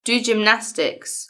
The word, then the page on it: do gymnastics: British English